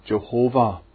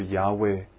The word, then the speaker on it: Yahweh: The vowels of Adonai were merged with Yod, He, Vav and He to produce Yehovah or Jehovah. The correct pronunciation is probably closer to Yahweh.